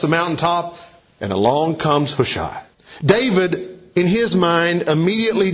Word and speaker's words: Hushai: And after he uttered these words, the narrator tells us a man by the name of Hushai arrives, or some pronounce it Hushi.